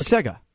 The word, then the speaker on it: Sega: No, it's Sega. Sega. Seg. Egg. Egg. Like egg. Seg.